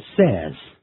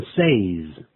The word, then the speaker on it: says: 如果用 say 这个动词，我们加了 s 念的时候念成 says, 而不要念成 says。